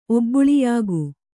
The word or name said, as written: ಒಬ್ಬುಳಿಯಾಗು